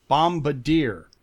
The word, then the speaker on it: bombardier: I think it's because it's the D I E R at the end. So like he was pronouncing it like brazier, like the the like a you know mirrored torch to light up a room. – He was yeah, just, but it's bomb bad ear, bombardier.